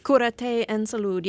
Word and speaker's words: Curate en Salud: Am I saying it right? Heal and Health? – Curate en Salud. Yes, yeah.